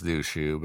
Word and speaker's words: Liuqiu: The boat left the port of Liuqiu, Taiwan. I believe I said that right. – Yeah, I think it's Liuqiu.